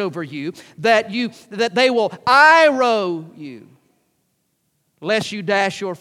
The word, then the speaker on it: airo: It is spelled A-I-R-O. It looks like arrow, but it's pronounced airo.